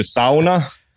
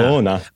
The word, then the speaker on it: sauna: And thank you for saying it correctly, because here in the United States, people say, do you want to, I went to a sauna. – Sauna. – But I learned in Finland, it's sauna.